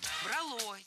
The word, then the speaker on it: брало: Но все равно так брало за душу, что сил не было человеческих. – Брало. Неверно. Правильный вариант – брало. Ударение ставим на корень.